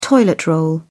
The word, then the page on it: toilet roll: British English